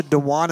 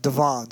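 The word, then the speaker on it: Dhawan: I've been pronouncing it Sacha Dewan in my head all along, but Matthew Sweet, who was there as one of the interviewers from the Blu-ray range, pronounced it Devon, so I'm going to go with Devon for the rest of this hour.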